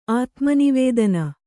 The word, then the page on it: ಆತ್ಮನಿವೇದನೆ: ātmanivēdane